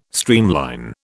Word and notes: streamline: British English

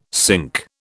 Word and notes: sync: British English

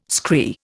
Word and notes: scree: Indian

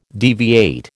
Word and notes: deviate: American English

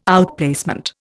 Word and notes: outplacement: Indian

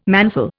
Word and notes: manful: Indian

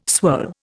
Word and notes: swirl: Indian

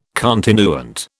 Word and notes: continuant: British English

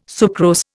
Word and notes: sucrose: Indian

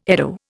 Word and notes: eddo: Indian

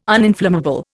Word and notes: uninflammable: Indian

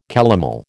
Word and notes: calomel: American English

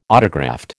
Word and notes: autograft: American English

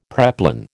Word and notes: preplan: American English

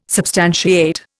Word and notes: substantiate: Indian

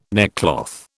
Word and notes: neckcloth: British English

